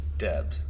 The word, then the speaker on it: Debes: Debs. – Oh. What? – Debs. – How do you spell that? – D-E-B-E-S.